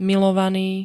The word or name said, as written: milovaný